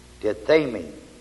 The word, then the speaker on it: tithemi: The word made is the Greek word T-I-T-H, long E-M-I, tithemi, which means to set or to appoint.